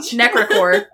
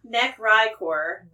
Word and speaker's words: Necrichor: I have been given the phonetics on the screen. – Necricor. Yeah, I hear you. – Necricor. – Necricor. – Okay. Necricor. – Necricor. And I will say I have spelled it phonetically every time I've written it.